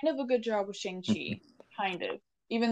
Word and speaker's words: Shang-Chi: It's Shang-Chi because it's like chi, like the energy inside of his body.